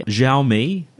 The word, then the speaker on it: Xiaomi: Now, Chinese company Xiaomi, depending on how you like to pronounce it, Xiaomi, doing smartphones and, well, lots and lots of other things.